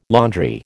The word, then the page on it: laundry: American English